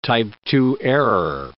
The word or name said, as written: type II error